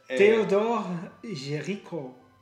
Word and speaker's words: Théodore Géricault: Théodore Géricault.